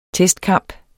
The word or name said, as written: TESTKAMPE